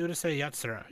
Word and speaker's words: Urusei Yatsura: No, it's actually Urusei Yatsura. – Urusei Yatsura. – No, it's not Sai. If it was Sai, then it would be an A. Yeah. – Yeah, yeah. Urusei Yatsura.